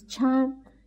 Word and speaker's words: Chan: The fact that now it's known as Zen, which is actually the Japanese pronunciation. In Chinese, it's Chan. In Korean, it's Son.